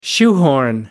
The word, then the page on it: shoehorn: American English